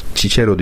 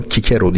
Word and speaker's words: Cicero: Ama mesela Cicero diye okuyoruz. Onun Kikero diye okunduğunu biliyorum.